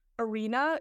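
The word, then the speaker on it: Are.na: And also Arena, it's spelled R.NA, like A-R-E.NA.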